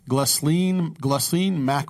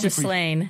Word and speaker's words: Ghislaine: Glaceline Maxwell. I'll never say it right. How long have we been trying to say this name? – How long, I think it's Ghislaine.